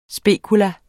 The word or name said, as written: spekulum